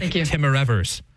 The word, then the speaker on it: Timmerevers: This is Bishop, and I'll pronounce it, Sarah, so you don't have to, Heinrich Timmerevers.